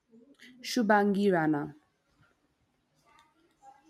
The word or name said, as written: Shubhangi Rana